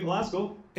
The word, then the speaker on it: Glasgow: And so you've got a bachelor's from the University of Glasgow. Am I saying that right? – Yep, University of Glasgow.